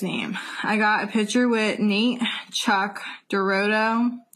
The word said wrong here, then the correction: Dorota: How do you say it? Dorota.